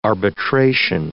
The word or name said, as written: arbitration